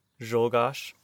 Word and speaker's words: Czolgosz: Czolgosz? – Czolgosz.